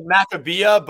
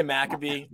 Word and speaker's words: Maccabiah: It's not the Maccabiah. It's the Mac. How do you say it? – It's like Maccabiah, but we say Maccabee.